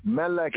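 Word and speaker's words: Melech (מלך): Well, Molech actually is like, okay, we say Melech. Melech is king in the proper pronunciation. Molech is king in a distorted pronunciation. So we have Melech, proper pronunciation, Melech, king, Melech.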